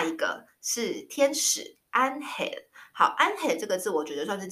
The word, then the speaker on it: ángel: a a e a 什么的发音没有这种发音变化音，发音很简单，所以它就是 a 发 r，所以就是照着西文发音 ángel，只有两三个简单的变化，可是你很快就会，你掌握了，你就都会念了。